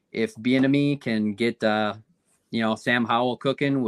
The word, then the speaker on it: Bieniemy: And, you know, I don't know if Sam Howell is the guy, but I do know that Eric Bieniemy, is that how you say it? Bieniemy? – Yeah. – Bieniemy. And it's an enemy.